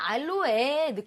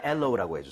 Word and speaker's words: aloe: 근데 이게 파닉스대로 근데 읽으면 알로에 느낌이 나긴 해요. – 알로에. 예. 하지만 알로우라고 해 주세요.